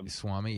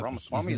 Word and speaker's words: Ramaswamy: Vivek Swami. – Yep. – Ramaswamy.